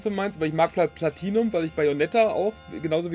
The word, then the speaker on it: Platinum: Ja, Platinum ist schon in Ordnung